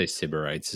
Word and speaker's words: Sybarites: Am I saying that right? Sybarites? Sybarites? – I'd say Sybarites is how it is. – Sybarites, yeah.